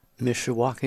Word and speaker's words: Mishawaka: Mishawaka, Indiana.